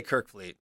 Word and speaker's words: Kerfoot: It's Kerfoot, right? Saying it correctly. – I say Kerfoot. I hope that's right.